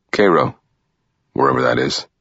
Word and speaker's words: Cairo: "That's how they pronounce the one in Egypt. The one in Little Egypt. They call that one Cairo.